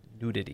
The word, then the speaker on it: nudity: Nudity. – He keeps messing it up. – How did you say it? He's saying adult nudies. – Say nudity. – Nudity. – It's three syllables.